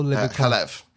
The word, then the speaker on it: Chalev: And the relationship he has with the letter writer, I'm going to get the name wrong, Glenn Chavel. What's that relationship? Like, how... – Chalev, isn't it? – Oh, sorry. Yeah, sorry. Glenn Chalev.